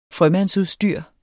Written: frømandsudstyr

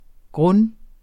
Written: grund

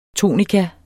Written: tonika

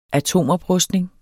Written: atomoprustning

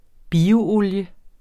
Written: bioolie

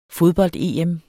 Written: fodbold-EM

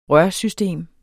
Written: rørsystem

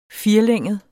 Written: firelænget

